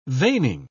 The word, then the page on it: veining: British English